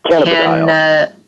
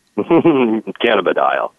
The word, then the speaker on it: cannabidiol: Can you pronounce it for me correctly, please? Somebody? – Cannabidiol. You go for it. – Cannabidiol. – Thank you. Cannabidiol. – Ding, ding. – Ladies and gentlemen, you have heard it right here on the show. That is the correct pronunciation, because I will murder it every time.